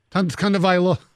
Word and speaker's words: Tagovailoa: You said to a tungo. – Tagovailoa. – Tagovailoa. You're right, I did.